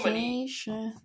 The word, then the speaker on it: Californication: California. Oh, Californication.